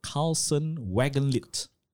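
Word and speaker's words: Carlson Wagonlit: No, is he was actually a tour counselor for corporate clients on business trips for Carlson Wagenlit.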